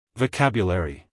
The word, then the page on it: vocabulary: American English; male